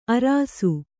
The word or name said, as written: ಅರಾಸು